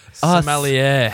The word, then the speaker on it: sommelier: How do you pronounce that? – Sommelier. – Okay, it's like smell the air. Smell the wine.